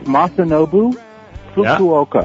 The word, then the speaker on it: Masanobu Fukuoka: How do you say his name again there? – It's Masanobu Fukuoka. – Yeah. Fukuoka, okay.